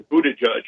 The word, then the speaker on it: Buttigieg: And you talked about Mayor Pete Buttigieg, you did a real research on how to pronounce the last name, which I think it's just judge at the end. You got to stick judge. Okay. The Buttigieg.